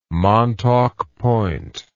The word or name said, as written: Montauk Point